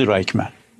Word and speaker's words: رایخمن: یه دانشگاه هم برگزارش میکنه، یه دانشگاه خصوصی، دانشگاه رایخمن یا به تلفظ رایخمن، یه دانشگاه خصوصی در اسرائیل.